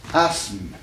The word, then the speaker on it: asthme: À tel point que dans les textes, vous trouvez, au lieu de I-S-M-E, vous trouvez I-Z-M-E. Voyez-vous ? Prenons un mot qui indique une maladie respiratoire. – Asthme. – Hein ? Donc, il a aussi, n'est-ce pas ? S-M, n'est-ce pas ? Faisons, écartons le TH de l'orthographe, n'est-ce pas ? Qui ne se prononce pas.